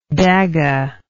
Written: dagga

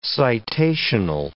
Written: citational

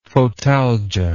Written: photalgia

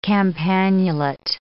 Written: campanulate

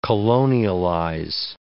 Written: colonialize

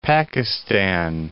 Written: Pakistan

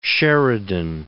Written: Sheridan

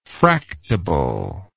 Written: fractable

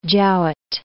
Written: Jowett

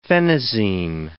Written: phenazine

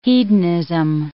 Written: hedonism

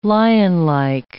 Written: lionlike

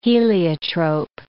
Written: heliotrope

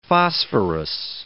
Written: phosphorous

